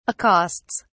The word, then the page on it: accosts: British English